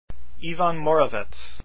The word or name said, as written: moravec